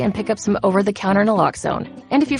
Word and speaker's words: naloxone: For the record, it's Naloxone. Nuh, like, is Naloxone addictive? Nuh. Lox, like Naloxone keeps humans safe, like locks keep bikes safe, and own, like Naloxone can save lives in our very own communities.